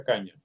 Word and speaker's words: Canha: Caña, ¿verdad? Canha, eso se pronuncia Caña.